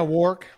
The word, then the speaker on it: Wark: Yeah, Wark rhymes with pork. Pork. York. Yeah.